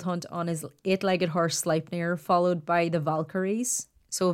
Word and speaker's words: Sleipnir: And I'm actually very proud of that because it's spelled S-L-E-I-P-N-I-R. I was like, I bet you I'm pronouncing that wrong. And Ewan happened to be playing World of Warcraft with a Danish guy and asked him spelled it out and asked him how to pronounce it. And he was like, Sleipnir?